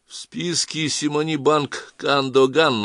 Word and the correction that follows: Кадагин: на острове Джерси. — Кадагин, — поправил Коваленко. — Что? — Правильно произносить банк Кадагин.